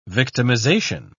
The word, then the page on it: victimisation: British English